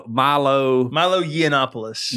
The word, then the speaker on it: Yiannopoulos: Yiannopoulos.